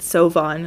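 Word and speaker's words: Siobhan: Like very Irish, like Sioban name. – Oh, that looks like Sioban or whatever. – Yeah, and I like looked up how to pronounce it, and it said Sovan, and I was like, okay, cool.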